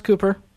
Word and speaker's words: Kuper: Is it pronounced Kuper? – Yep.